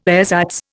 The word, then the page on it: ersatz: Indian